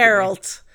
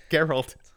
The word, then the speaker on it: Geralt: Geralt. How do you pronounce his name? Geralt. – Geralt.